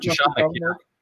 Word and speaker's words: Jeschonek: I probably butchered that, didn't I? – It's close enough, man. Close enough. Jeschonek.